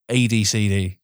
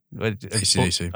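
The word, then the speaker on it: AC/DC: Someone can't afford to buy the license for fucking ADCD. – ACDC.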